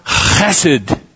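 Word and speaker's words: chesed (חסד): It's a very special Hebrew word. That's pronounced chesed.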